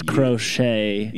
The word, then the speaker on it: crochet: What is crochet? – Yeah. You know, how do you feel about the silent T at the end? Is it needed? – Oh, you know, I have...